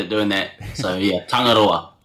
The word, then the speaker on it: Tangaroa: Guys, forgive me if I say it wrong, Tangaroa, which is out now, guys. Did I say it correctly? – Yeah, you just got to roll the R there, so...